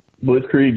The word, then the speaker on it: Blitzkrieg: Evan Courageous beat Blitzkrieg. Is that how you say his name? – Blitzkrieg, yeah.